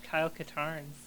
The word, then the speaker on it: Katarn: They have Kyle Katarns.